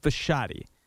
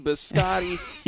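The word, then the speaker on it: Bisciotti: He doesn't do a lot of interviews, so a lot of people don't know the proper pronunciation. It's Steve Biscotti. – Biscotti.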